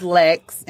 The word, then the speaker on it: Lex: I'm going to go with, my name is Lex, and it rhymes with sex.